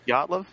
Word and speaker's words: Dyatlov: Dyatlov? Is that how you'd say it? – Yeah, that's not cryptozoology. – D-Y-A-T-L-O-V.